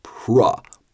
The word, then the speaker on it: pro: It's pro, professional. But when we see P R O, that's always going to be pro.